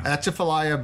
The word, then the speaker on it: Atchafalaya: Atchafalaya Basin, mm-hmm.